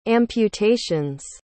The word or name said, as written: amputations